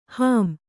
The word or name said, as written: ಹೊಂ